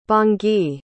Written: bangui

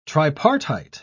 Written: tripartite

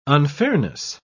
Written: unfairness

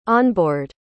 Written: onboard

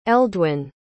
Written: eldwyn

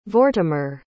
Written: Vortimer

